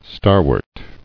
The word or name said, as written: Starwort